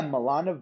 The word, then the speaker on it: Milana: Is it Milana? – Yeah, Milana Weintraub.